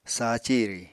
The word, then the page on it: sa ciri: Apatani